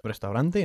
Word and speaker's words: restaurant: ¿Cómo pronunciábamos nosotros restaurante en inglés? – Restaurant. – Restaurant, así es, con dos sílabas, nada más.